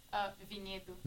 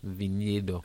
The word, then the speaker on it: Vinhedo: More specific, from Vinhedo, is a very small town. – Can you pronounce that? – Vinhedo. – Vinhedo.